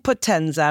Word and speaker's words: Potenza: Born in Potenza, and I hope I say this properly, Benedetto studied physics. – You said well.